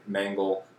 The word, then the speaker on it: Mengle: You can find me on Facebook and Timmy Mangle, T-I-M-Y, because I'm some kind of fucking person that spelled my name wrong. So T-I-M-Y Mangle, M-E-N-G-L-E.